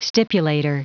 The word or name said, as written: stipulator